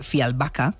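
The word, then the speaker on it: Fjällbacka: Es verdad que sus novelas parecen guiones, Pues resulta que los crímenes de Fialbaca, que es como se pronuncia, ella nos lo dijo así la última vez, están y vuelven esos protagonistas de todo esto y también, vamos a añadir, de misterio, de una cuenta pendiente del pasado.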